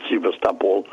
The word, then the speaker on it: Sevastopol: We were at Sevastopol, where the, or they pronounce it Sevastopol, where the battles were going on in the Crimean War.